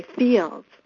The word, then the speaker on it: Fields: And I always say, Fields. Fields? No, Fields. Oh, then I say, you know, like the flowers that are in the fields, and people say, oh, Fields, Fields.